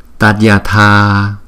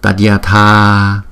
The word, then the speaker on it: Tathagata: Phần tiếng phạn chúng ta sẽ đọc như sau: Tathagata, Tathagata.